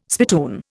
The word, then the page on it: spittoon: Indian